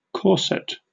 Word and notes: corset: British English

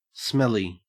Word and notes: smelly: Australian English

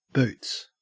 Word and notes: boots: Australian English

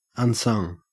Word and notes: unsung: Australian English

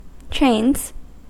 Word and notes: trains: American English

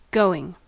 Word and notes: going: American English

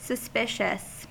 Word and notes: suspicious: American English

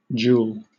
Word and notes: joule: British English

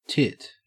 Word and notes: tit: Australian English

